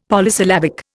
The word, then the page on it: Polysyllabic: Indian